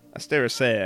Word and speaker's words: Asteraceae: I don't know how to say the AE thing. – Asteraceae, I think, yeah. – Okay. – Like an AY type of sound.